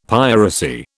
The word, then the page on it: piracy: British English